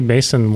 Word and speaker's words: Macsen: I think it's spelled... – Maxon. – Mason. Mason. M-A-C-S-E-N.